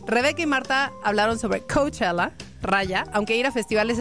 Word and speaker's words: Coachella: Rebeca y Marta hablaron sobre Coachella.